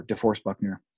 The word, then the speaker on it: DeForest Buckner: So again, traded their first round pick for DeForest Buckner.